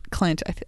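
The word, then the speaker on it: Clent: And we also get more of Klempt, who is... – Are you saying Klempt or Klent? – Klent. I said it wrong.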